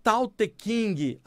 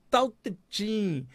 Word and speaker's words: Tao Te Ching: E ela me disse que não tem o som do G na pronúncia. Então eu falei, como é que é? Ela falou, produto Qing Ling, né? E aí eu aprendi que não era Tao Te King a obra, era Tao Te Ching.